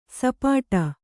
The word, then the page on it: ಸಪಾಟ: sapāṭa; kannada